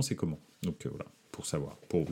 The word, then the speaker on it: Coman: mais son nom se prononce comment pour de vrai. Euh, c'est pas euh, c'est pas Coman.